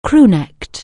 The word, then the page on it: crew-neck(ed): anglais